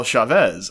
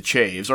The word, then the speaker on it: Chaves: No, and I swear, because I thought it was Paul Chavez as well, because that's how it's spelled, and everywhere else in the world, that's how it's pronounced. But no, he Canadianized his last name to Chaves, or rather his family did, because they live in Kingston, which is extremely white.